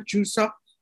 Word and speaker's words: Chuseok: awesome and are those traditional Chuseok? am i pronouncing that right Chuseok. the name yeah you're pronouncing it perfectly yeah uh,